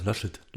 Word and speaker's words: Laschet: Laschet.